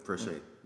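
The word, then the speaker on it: Fréchet: The coach's name is Joel Frech, right? – Frechet. – Sorry, Joel, I didn't pronounce it with the French accent.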